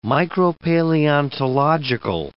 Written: micropaleontological

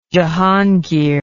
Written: jehangir

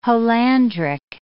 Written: holandric

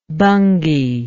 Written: bhangi

